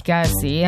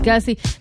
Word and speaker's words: Kelce: Nos, Taylor Swift és Travis Kelce vagy Kelce, hát én akárkivel találkozom, aki amerikai mondjuk a TikTokon és kiejti a nevét, mindig kétféleképpen mondják, hogy Kelce vagy Kelce, nekünk teljesen mindegy, az a lényeg, hogy hivatalosan is összekötötték az életüket.